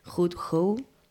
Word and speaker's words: Hood Hoe: Bueno, chicas, pues ha sido muy interesante esta entrevista, esta presentación del colectivo Hud Hou. Por fin lo digo bien.